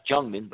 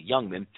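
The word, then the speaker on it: Jungmann: It looks like it's pronounced Jungman, but it's actually Youngman.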